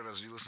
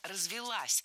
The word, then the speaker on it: развилась: Но такого вида транспорт, это все-таки когда уже гужевая тяга прям развилась, появились все-все-все аксессуары, и люди перестали ходить пешком недавно. – Развилась, неверно, правильно, развелась. Ударение ставим на окончание.